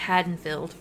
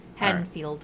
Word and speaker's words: Haddonfield: First of all, it's pronounced Haddonfield. – Is it Haddonfield? I thought it was Haddonfield. But Haddonfield. Okay, Haddonfield. – Haddonfield, right.